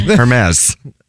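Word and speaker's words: Hermès: So wait, the store is spelled Hermaze? – No, not like Hermes. – Yeah. Whoa. Oh my God. Hermes. – No, that's Hermes. – No, it's French. Hermes. – Hermes. – No, I know spelling. That's Hermes. No, I've seen the store. It's the Hermes store. – Well, I'm glad you didn't go in there and call it that. – They're silent letter.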